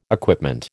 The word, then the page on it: equipment: American English